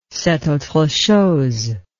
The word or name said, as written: c'est autre chose